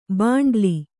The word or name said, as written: ಭಂಡೀಲ